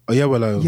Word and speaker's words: Oyelowo: David Oyelowo. I can never pronounce it. David Oyelowo. – Yes. – Oyelowo.